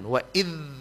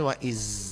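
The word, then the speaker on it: wa idh (وإذ): Wa idh, jangan sampai wa iz, itu huruf zai. Ilo balangan de zai itu ilah pala de zai. Wa idh huruf za.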